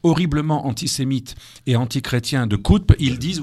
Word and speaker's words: Qutb: Alors ça, c'est Sayyid Qutb, j'ai du mal à prononcer. – Oui, Qutb.